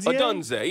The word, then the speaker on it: Odunze: It's Rome Odunze. – Odunze.